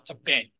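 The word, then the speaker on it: Ben (בן): 儿子叫 Ben，石头是 Aben 阿本，所以你发现发音是很类似的。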